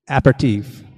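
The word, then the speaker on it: apéritif: One of them, and I don't know how to pronounce this, but is it aperitif? Is that how you say that? Aperitif? – Aperitif.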